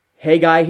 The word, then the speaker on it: Haggai: It's the book of, well, I pronounce it Haggai, but I think you say Haggai here.